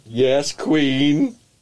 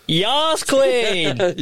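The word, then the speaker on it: Yas Queen: Yes, Queen. – What? – Sorry? – What? – I don't know why. No, the pronunciation is... The pronunciation is... Yas, Queen!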